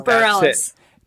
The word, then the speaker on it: Bareilles: Bareilles.